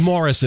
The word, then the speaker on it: Maurice: That's Morris. In the UK, it's pronounced Morris.